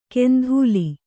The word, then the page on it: ಕೆಂಧೂಲಿ: kendhūli